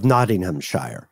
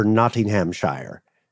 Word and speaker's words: Nottinghamshire: My tongue is tripping over Nottinghamshire. Is it Nottinghamshire? Nottinghamshire. – Well, I think the Shire, you could say it as just Nottingham or Nottinghamshire.